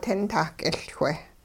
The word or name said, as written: tintah-k'iLchwe